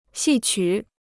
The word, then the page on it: 戏曲: xì qǔ